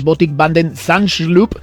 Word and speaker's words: Botik van den Zandschulp: No antes de las 6 de la mañana se medirá al holandés Botik van den Zandschulp. Más o menos se pronuncia de esta manera